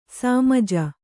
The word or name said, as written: ಸಮಜ